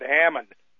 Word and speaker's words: Haman: I pronounce that Haman, but I guess you could pronounce it Hammon, spelled H-A-M-A-N.